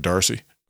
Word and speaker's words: D'Arcy: now how do you pronounce that? She was like, D'Arcy.